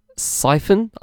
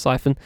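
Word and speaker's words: Psiphon: When using a popular VPN, Siphon, I'm assuming that's how you say it, it's P-S-I-P-H-O-N, I'm assuming that's Siphon.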